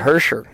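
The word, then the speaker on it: Hischier: Hersher.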